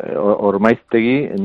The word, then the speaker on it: Ormaiztegi: dime si lo pronuncio bien, que creo que era Ormaiztegi, Ormaiztegi, ¿dónde está el acento? – Ormaiztegi, no, en Ormaiztegi fabricamos los autocares y autobuses también interurbanos.